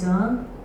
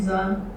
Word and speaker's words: Zohar: So to say just briefly about myself, so my name is Zohar. Great to say it with the Hebrew pronunciation. So I'm going to say it again, Zohar, just for my own enjoyment.